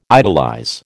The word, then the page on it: idolize: American English